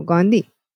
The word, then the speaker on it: Gandhi: Aztán az első nem amerikai, az 1930-ban Gandhi volt. Vagy Gandhi. Hogy kell mondani, jó? Gandhi? – Uh-huh.